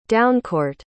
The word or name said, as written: downcourt